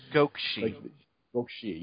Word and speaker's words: Sengokushi: Yo-Kai Sengokushi? – Gokushi. Yeah, you don't really pronounce that U.